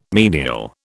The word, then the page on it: menial: British English